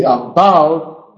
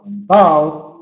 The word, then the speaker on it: unbowed: Lo vais a decir, pero unbowed empieza con U. No es cuestión de la letra con que empieza. Es cuestión de la sílaba remarcada. Y unbowed, unbowed, unbowed, la sílaba remarcada empieza con B.